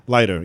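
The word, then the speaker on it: Leiter: Solitaire, pronounce the name right? – Is it Lighter? – Lighter, yeah. Yeah, Sol Lighter.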